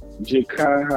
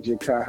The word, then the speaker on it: Jahki: Jahki Howard. – Yeah, Jahki Howard. – Jahki Howard, yeah. I think it's J-A-H-K-I Howard, Jahki Howard.